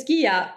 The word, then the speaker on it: Ghia: And our name is Ghia, like pronounced with a hard G.